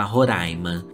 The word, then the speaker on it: Roraima: No entanto, só consigo ler aquilo que não deveria estar lendo, como os sumérios, a pronúncia correta por Roraima, ambas são corretas, mas em Roraima se fala Roraima.